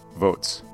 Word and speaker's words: Voatz: And what came out of that about 1 year later was a new startup called Votes. That's spelled V-O-A-T-Z, which, annoyingly, sounds exactly like the word votes.